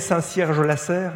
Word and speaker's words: Saint-Cierge-la-Serre: J'ai bien prononcé Saint-Cierge-la-Serre Tout juste.